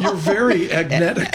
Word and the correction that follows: agentic: Yeah, exactly. I'm getting there. Agentic. Yeah. All right. – And also, remember this. – Is that how you say it? Agentic? – Agentic AI. – Agentic. That's what our folks here say.